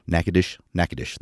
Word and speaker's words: Natchitoches: Now, there's also Nacogdoches, and they're spelled almost exactly the same, but if you go to Natchitoches, God, I'm not even sure I'm saying it right. Natchitoches, that's it.